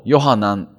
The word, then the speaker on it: ヨハナン: ヨハネというのは、ギリシャ語の発音から来ているんですけども、ヘブライ語だとヨハナンと言います。ヨハナン、ヨーっていうのはイエーとかヨーとかっていうのはヤーウェ、神はっていう意味です。で、ヨハナン、ハナンっていうのは、ハンナと同じ語源で恵みって意味です。